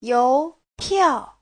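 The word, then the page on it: youpiao: you2piao4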